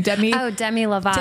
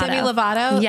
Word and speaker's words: Demi Lovato: Demi Lovato. – Demi Lovato.